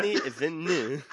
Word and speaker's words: Denis Villeneuve: Denis Villeneuve.